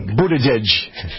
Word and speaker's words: Buttigieg: Peter, the aforementioned Buttigieg. – Buttigieg. – Thank you. Well, how do you get that out of this? That isn't how it's spelled. – Buttigieg. – That's no, how it's pronounced.